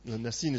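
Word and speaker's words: Nisan: Nesin is actually how it's said in the Hebrew.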